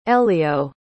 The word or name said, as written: elio